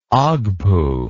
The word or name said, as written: ogpu